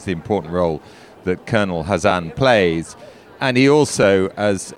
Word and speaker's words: Hazan: And if you've listened to the interview with him already, you'll know that he brought me up on the pronunciation of his name. It is Hazan and not Kazan.